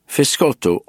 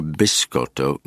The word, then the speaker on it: biscotto: Fiscotto, or biscotto, means twice cooked in Italian. The first spelling is the Calabrese pronunciation.